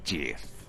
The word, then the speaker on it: gif: Like a gif. That's what gifs do, isn't it? They're just endless. – راستش درسته. ولی بعضیا اونو تلفظش میکنن جیف.